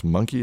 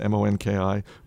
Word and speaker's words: Monki: Secret weapons are the very popular and super trend sensitive Sweden sub brands like Monki is how you would pronounce it, Monki, Weekday, Cheap Monday, COS, and other stores that they haven't pushed internationally, and an online platform being introduced in selective countries.